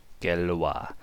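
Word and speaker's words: Gallois: Galois, is that how you pronounce the guy's name? – Yes. – Galois.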